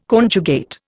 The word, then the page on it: conjugate: Indian